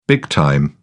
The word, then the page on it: big time: British English